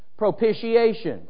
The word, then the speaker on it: propitiation: Now, the first word I want to focus on this morning is the word propitiation. The T is pronounced like a SH. Propitiation.